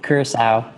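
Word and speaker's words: Curaçao: How would you pronounce that? – That's right. Yeah, Curacao. – Curacao. Here we go. – Yeah, they've got that little squiggly.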